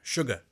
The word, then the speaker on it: Shuga: Yes, it's Shuga.